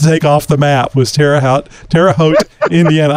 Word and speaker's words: Terre Haute: Terre Haute, Indiana.